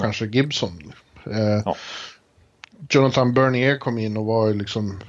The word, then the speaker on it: Bernier: Och så uttalar de Berniers namn bättre än vad vi gör. Det är i och för sig inte så svårt. Men de lyckas ju sätta eh, Bernier, Bernier konstant där.